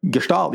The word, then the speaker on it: Gestalt: Gestalt, yeah. Gestalt. It's a German word. It's a German word for structure, okay.